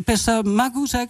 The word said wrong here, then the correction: Pesta Bagus: zeg ik dat goed? – Ja, Pesta Bagus.